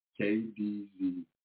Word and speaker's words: KDZY: So this next one here is going to be by KDZ. That's exactly how you say it, KDZY.